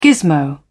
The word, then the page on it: gismo: British English